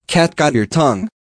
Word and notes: cat got your tongue?: American English

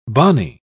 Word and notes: bonnie: British English

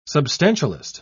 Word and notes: substantialist: British English

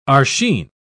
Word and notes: archine: British English

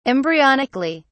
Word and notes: embryonically: British English

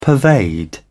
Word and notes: pervade: British English

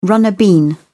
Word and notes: runner bean: British English

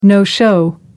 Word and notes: no-show: American English

